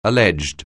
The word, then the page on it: alleged: English